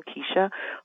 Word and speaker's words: Kecia: Well, I have a Twitter handle at Keisha Gaither, MD, and my name is spelled K-E-C-I-A. That's my first name, Keisha. Last name, G-A-I-T-H-E-R.